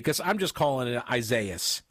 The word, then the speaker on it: Isaias: Isaias. – You don't think it would sound like Isaias the way it's spelled, but that's how it's pronounced.